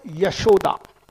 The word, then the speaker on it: Yashoda: This word Yashoda, it consists of two syllables. I mean, those of you who are familiar with Hindi will be able to get this. Yash and Da.